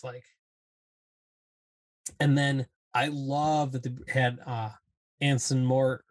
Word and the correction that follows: Anson Mount: or however you say his name. Anson Mort. Right, is that right? – Anson Mount, that's it. Anson Mount as Black Bolt.